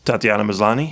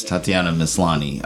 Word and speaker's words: Tatiana Maslany: Tatiana Maslany? – Yes, Tatiana Maslany.